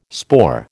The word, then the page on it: spore: American English